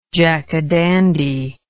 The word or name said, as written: jackadandy